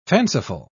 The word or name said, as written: fanciful